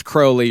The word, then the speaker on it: Crowley: It is pronounced Crowley. You're supposed to say Crowley, but come on, Mr. Crowley.